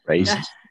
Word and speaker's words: Raíces: Ra, like the god Ra. E ses. – Okay, gotcha. Raises.